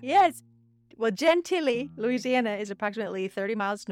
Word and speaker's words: Gentilly: The Francioni family lived in Gentilly, Louisiana. – Gentil. – It's G-E-N-T-I-L-L-Y. – Oh. That is Gentilly.